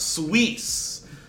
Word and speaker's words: Suisse: He said it's pronounced Suisse.